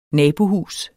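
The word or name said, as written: nabohjælp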